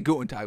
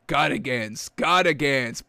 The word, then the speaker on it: Gutekunst: I want to say Gutentag, but it's not Gutentag. Packers general manager Brian Gutekunst.